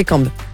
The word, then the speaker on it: Cambes: Ça s'écrit, je sais pas si je le prononce bien, C-A-M-B-E-S. Tu connais ce village? Ça a l'air, sinon c'est Cambes. – Ouais, voilà. – Donc je pense que c'est Cambes. – Ouais, c'est Cambes, t'as raison.